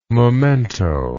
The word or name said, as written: momento